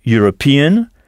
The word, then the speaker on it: European: Si un Anglais se dit an Englishman et le mot pour européen est European avec le son U au début, ça s'écrit E majuscule UROPEAN, European, comment dira-t-on un Anglais est bien sûr un Européen? – An Englishman is of course a European.